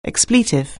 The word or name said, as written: expletive